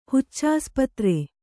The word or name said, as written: ಹುಚ್ಚಾಸ್ಪತ್ರೆ